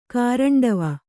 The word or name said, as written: ಕಾರಂಡವ